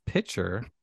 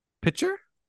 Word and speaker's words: picture: So for best picture, you I would also like to pronounce or like to say that you pronounce picture weird. – Pitcher? – Pitcher. Yeah. Like a pitcher, like a baseball. – You're pouring it. No, it's picture.